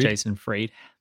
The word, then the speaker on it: Jason Fried: Jason Fried. – So do I, usually. Jason Fried. So Basecamp is located in Chicago. So that's pretty cool. So Jason Fried will be there to talk with us all, who is an amazing person to follow on Twitter.